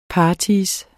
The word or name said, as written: partyer